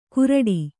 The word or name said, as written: ಕುರಡಿ